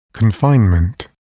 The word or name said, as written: confinement